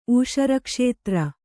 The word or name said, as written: ಊಷರಕ್ಷೇತ್ರ